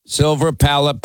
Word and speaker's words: Silver Palate: I eat silver plate. – Silver palate pasta sauce.